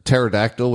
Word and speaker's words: Pterodactyl: Pterodactyl with a silent P?